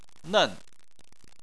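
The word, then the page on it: nen: tone 4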